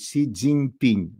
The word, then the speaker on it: Си Цзиньпин: Кроме заявления Си Цзиньпиня, вот Миш Швец меня все время поправляет, что я должен правильно говорить Си Цзиньпинь. Да, ну как-то выпадает это, Си Цзиньпин лучше получается.